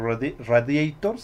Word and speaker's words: Radiator: También un, un grupo de rock galés llamado Super Fury Animals incluye una canción llamada Chupacabras en su álbum denominado Radiator, o Radiator sería su pronunciación, en 1997.